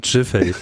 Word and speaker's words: Giffey: Und jetzt pronounced Giffey. – Giffey, Giffey.